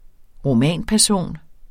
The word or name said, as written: romanperson